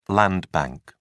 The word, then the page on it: land bank: British English